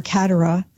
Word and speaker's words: Kateri: But eventually there would be priests that would come in, help form her in the faith, and she would be baptized Kateri, or Katara, as some pronounce it, named after the great Saint Catherine of Siena.